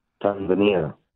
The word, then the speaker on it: Tanzania: Do you say Tanzania or do you say Tanzania? – Tanzania. – Tanzania. There we go.